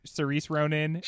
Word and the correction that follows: Saoirse Ronan: Saoirse Ronan.